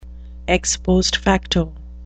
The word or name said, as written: ex post facto